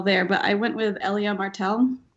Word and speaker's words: Elia Martell: The pronunciation is said several times in the scene between the mountain and the viper as he exclaims to the mountain, Elia Martell, you will say her name.